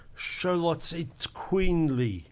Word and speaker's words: Xoloitzcuintli: I don't even know how to pronounce it properly. – Say it again. – It's spelt X O L O I T Z C U I N T L I.